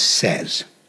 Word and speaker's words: Szasz: Now we have Thomas Szasz. Nobody can pronounce it or spell it, but it is S-Z-A-S-Z.